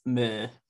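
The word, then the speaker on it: MØ: I would say the kicker for me is just like Mo, who I think you're supposed to pronounce like Meh or Muh. – Yeah, it's Meh.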